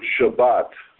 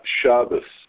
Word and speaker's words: Shabbat: The word Shabbat ends in a tav. It's not Shabbos. Total corruption.